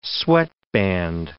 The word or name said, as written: sweatband